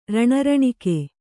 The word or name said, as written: ರಣರಣಿಕೆ